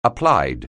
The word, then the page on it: applied: anglais